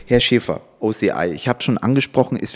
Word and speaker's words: OCI: Also äh, wie Sie schon äh, eingangs richtig gesagt haben, OCI, äh, also im Englischen ausgesprochen, auf Deutsch geschrieben natürlich OCI, Otto Cesar Ida, wie ich es immer buchstabiere, wenn mich jemand fragt, ähm, Dahinter steckt Outlet Centers International.